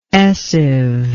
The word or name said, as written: essive